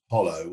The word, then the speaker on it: Jolo: I think in particular is an island spelt Jolo, but actually pronounced Hollow, which was Abu Sayyaf's stronghold.